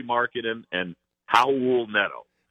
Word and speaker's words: Raul: And in fact, we had Howell on our Cavs HQ show, and the Americanized, the English version with the R is Raul, but the correct Hispanic Brazilian pronunciation is Howell, and that's what he goes by.